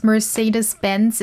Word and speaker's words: Mercedes-Benz: Okay, first, Mercedes-Benz is pronounced Mercedes-Benz.